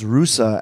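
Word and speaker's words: Russa (ルーサー): And so then there's, we talked about this earlier about the L's in Luscious Lenny, but Luther would be pronounced Russa. And I can even see how it's written in katakana, which is the Japanese alphabet. Russa.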